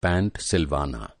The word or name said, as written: पैंट